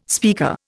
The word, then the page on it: speaker: Indian